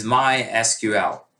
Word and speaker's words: MySQL: And the traditional pronunciation preferred by the founder is MySQL, like YMCA, MySQL, right?